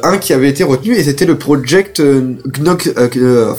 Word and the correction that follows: Knoxville: enfin putain j'arrive pas à le prononcer, Knoxville, c'est ça.